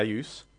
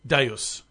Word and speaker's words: Deus: Without being racist, just to cut through the man's accent, the games he's playing at the moment are Elysium and how do you pronounce it? – Deus. – Deus. – As in Deus Ex Machina.